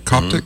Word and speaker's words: Coptic: Coptic? – Mm-hmm. Yes. – C-O-P-T-I-C?